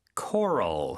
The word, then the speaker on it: coral: Coral, C-O-R-A-L. – 네, 그렇죠. Coral. 우리는 색깔을 얘기할 때 코랄색 이렇게 표현을 하는데 영어로는 coral 이렇게 발음하는 것도 우리가 익혀두면 좋겠네요.